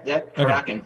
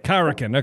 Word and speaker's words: Karrikin: Karrikin. – Okay. Karrikin. – That's Karrikin.